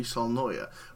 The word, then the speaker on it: Saulnier: I'd never heard of Jeremy Saulnier, I'm not even sure how to pronounce his name, to be perfectly honest with you.